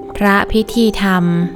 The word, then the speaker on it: พระพิธีธรรม: พระพิธีธรรม